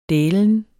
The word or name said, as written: dælen